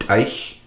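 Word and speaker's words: t'ayc: So when you have an A, Y in our combined together, which is very common, like in the word Teich, which is used in most Nukhalk sentences, you would spell that Teich. T apostrophe A-Y-C. So the A-Y makes an I sound.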